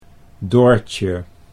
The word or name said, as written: Doortje